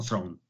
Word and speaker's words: Thrawn: Hay un personaje ahora de la Guerra de las Galaxias que es el almirante, bueno, ahora lo llaman Thrawn, ¿vale? Aquí lo hemos llamado Thrawn toda la vida, pero bueno, en la pronunciación, ¿no? En inglés americano, Thrawn, ¿no?